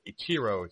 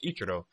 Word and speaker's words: Ichiro: Ichiro just sounds horrible. It's Ichiro.